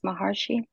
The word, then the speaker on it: Maharshi: I love the quote by an Indian sage named Ramana Maharshi, I believe it's pronounced Maharshi.